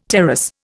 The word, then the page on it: terrace: Indian